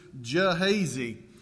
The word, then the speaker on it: Gehazi: Gehazi, it's spelled with a G, but my pronunciation guides in my Bible suggest to me the proper way to pronounce this is Gehazi.